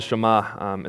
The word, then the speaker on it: Shema: This text that we read before, starting with, Hear, O Israel, the Lord your God is one, that text, it says, it's called the Shema, it's how you say it in Hebrew,